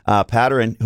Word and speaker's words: Patterin: He kind of went to help out, I'm not even sure, Patterin, is that how we pronounce it? Good. – Yeah, Patterin. Early in the season yet. New guys. Patterin, who looked like he was kind of beat, but at the same time, he had an angle on the guy.